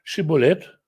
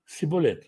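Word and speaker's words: шибболет: Греки не могли произнести звук «Ш», поэтому Иисус вместо Иешуа, Иерусалим вместо Иерушалаим, Моисей вместо Моше и так далее. То есть это такая вот, такой вот дефект речи был у ефраимлян. И они говорили ему, скажи «шиболет», и он говорил «сиболет». Это стало термином в лингвистике, как какое-то слово, произношение которого определяет принадлежность, определяет как бы культурную принадлежность человека. Потому что он не мог сказать правильно слово «шиболет».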